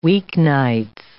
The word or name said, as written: weeknights